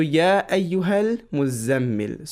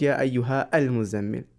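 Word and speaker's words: Ya Ayyuhal Muzammil (يا أيها المزمل): So, Ya Ayyuhal Muzammil. So, Alif al-Wasl is silent. Al-Muzammil becomes Ya Ayyuhal Muzammil without Ya Ayyuha Al-Muzammil.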